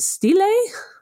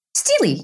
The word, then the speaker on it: stela: Now, this pronunciation as well, is it stelae? – Stelae, yes. – Stella? – Basically, just say stela or stela. And that'll work for the singular and the plural.